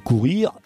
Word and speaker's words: courir: Courir, c'est comme courrier, mais avec un seul R. Alors, je crois que c'est moi qui avais mal prononcé, c'est pas courrier, c'est courir.